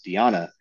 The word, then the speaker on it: Diana: I recognize the first name because my wife's name is spelled like Diana, but because she's Colombian, it's pronounced Diana.